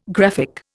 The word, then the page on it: graphic: Indian